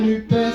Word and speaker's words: Nupes: Moi, je dis qu'il faut dire Nupes. Si on ne dit pas tout, si on ne prononce pas le S, on oublie un bout.